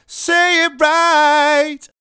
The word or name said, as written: Say It Right